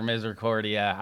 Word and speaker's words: Misericordia: I messed up Misericordia last year. Misericordia. I had no idea how to pronounce that name last year, Misericordia.